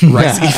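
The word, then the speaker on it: Rhys Ifans: I'm pretty sure it's pronounced Ray. – Actually, I think it's Rice. – Rice. – Rice Evans. I swear, everyone pronounces it differently. It's Rice.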